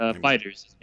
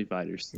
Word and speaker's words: FighterZ: So, basically, we're going to be talking about Dragon Ball FighterZ, I guess, is the preferred pronunciation of that. FighterZ, however you want to word it. – FighterZ. It is officially FighterZ.